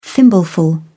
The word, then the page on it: thimbleful: British English